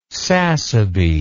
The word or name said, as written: sassaby